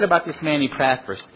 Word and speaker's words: Epaphras: Well, in the South, we say Epaphras.